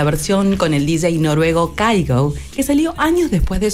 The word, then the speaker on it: Kygo: Sí, acá en Argentina le dicen Kygo, pero en realidad se pronuncia Kygo. Es noruego, así que ya saben, Kygo.